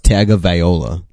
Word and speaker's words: Tagovailoa: It's actually pronounced Tagovailoa.